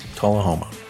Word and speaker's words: Tullahoma: It's in Tennessee, and I hope I'm going to pronounce this right, Jerry. Correct me here. Is it Tullahoma? – Yes, Tullahoma.